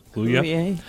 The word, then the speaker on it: Kaluuya: Is that how you say his name? – Kaluuya. Kaluuya.